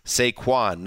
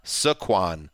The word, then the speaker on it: Saquon: It's Saquon, not Saquon.